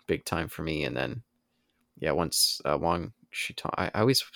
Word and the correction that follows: Wan Shi Tong: Wan Shi Tong. – Wan. There you go. I always want to say Wong. – No, yeah, it's W-A-N, excuse me, not W-O-N-G.